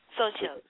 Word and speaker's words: Xochitl: And your other books for children include, how do you pronounce it, Xochitl? – Xochitl.